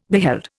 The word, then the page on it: beheld: Indian